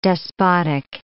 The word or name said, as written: despotic